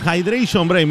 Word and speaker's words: Hydration Break: Hydration Break, me enseñó la pronunciación Pedro Goñi. Si estamos correctos, ¿no, Pedro? – Correcto, tiempo de hidratación mejor.